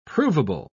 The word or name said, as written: provable